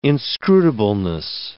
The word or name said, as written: inscrutableness